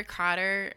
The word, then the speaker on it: Qader: Yeah, I'm Heather Cotter. My last name is Q-A-D-E-R. So it's Heather, rhymes with feather. Q-A-D-E-R.